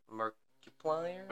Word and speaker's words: Markiplier: Is it Markiplier? – Markiplier. – What's Markiplier? – Markiplier.